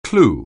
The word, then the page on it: clue: anglais